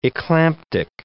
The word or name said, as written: eclamptic